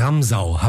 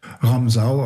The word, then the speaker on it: Ramsau: Heute bin ich in Hintersee in der Ramsau. Habe ich das richtig gesagt? – Ramsau, aber ansonsten war es Ramsau.